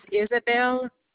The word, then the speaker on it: Isabelle: First name is Isabel, I-S-A-B-E-L-L-E, and the last name is Dubois, D-U-B-O-I-S.